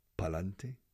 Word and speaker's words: pa'lante: And so, pero es una contracción como un piano, Para adelante are six syllables. Pa'lante are only three syllables.